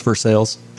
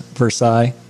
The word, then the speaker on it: Versailles: Here in Central Kentucky, there are three locations in Georgetown, Lexington, and Versailles, however you would like to pronounce that. For those of us local, it's Versailles. Out-of-towners, Versailles.